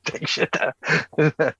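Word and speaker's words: Takeshita: Take Shita.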